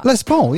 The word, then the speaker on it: Les Paul: How do you pronounce it? Is it a Les Paul guitar? – Les Paul, yeah. – Yeah, is that right? – Les Paul, yeah.